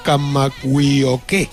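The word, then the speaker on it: Kamagui-Oke (Kamakawiwoʻole): De cualquier caso, en el caso de Israel Kamagui-Oke, yo creo que aquí el principal hándicap no son los kilos, es la pronunciación. Porque a ver si sois capaces de repetir conmigo Kamagui-Oke.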